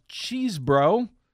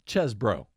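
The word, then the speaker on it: Chesebro: often known as Cheesebro, though his name is actually pronounced Chesbro.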